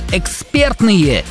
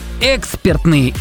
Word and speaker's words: экспертные: В данном прилагательном, образованном от слова «эксперт», сохраняется ударение на втором слоге, на чем сходятся абсолютно все словари, поэтому правильно говорить еще раз, как? – Экспертные.